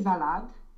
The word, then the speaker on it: Salat: Der frische Salat. – Cu T la urmă. Salat.